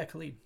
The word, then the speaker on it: Khalid: Um, and is it is it Khalid? Is that how you say his name? Yeah, Khalid. Mm-hmm.